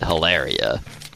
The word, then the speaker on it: Hilaria: What's her name? Hillary, right? Hillary. – I think she pronounces it Hilaria.